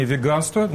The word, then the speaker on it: веганство: То есть это академический словарь, да, правильное ударение – веганство. Ну, то есть по аналогии с вегетарианством. То есть это русский язык, русское слово – веганство.